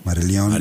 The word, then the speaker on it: Marillion: Marillion.